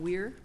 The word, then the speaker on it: Wiehr: Is your last name Weir? – That's correct. – Like W-E-I-R? – W-I-E-H-R, silent H.